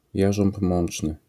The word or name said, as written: jarząb mączny